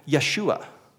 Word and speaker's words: Yeshua: In Hebrew, the way you would actually pronounce that name, maybe you have heard this somewhere on a YouTube video or something in a conversation, the name of Jesus in Hebrew is actually Yeshua.